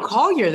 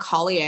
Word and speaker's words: Collier: No, Collier, that's it. I know people think it's fancy. It's not Collier. It's just regular.